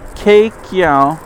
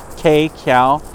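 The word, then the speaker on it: Queqiao: The Tiandu 1 and Tiandu 2 satellites are due to launch along with Keqiao. It looks like Keqiao. I'm sure in Chinese it's pronounced differently. It's Q-U-E-Q-I-A-O, a lunar communications relay satellite to support upcoming Chang'e lunar Farside and South Pole missions.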